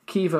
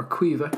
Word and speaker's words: Caoimhe: C-A-O-I-M-H-E. Kiva or Quiva, depending on what part of the country you're from. I think it's Quiva the south and Kiva in the north.